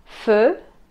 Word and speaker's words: feu: Feu, feu, jeudi, milieu, vieux, vieux, milieu, feu. Si se fijan, se escribe EU, pero es un poco parecido a la E.